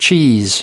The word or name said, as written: cheese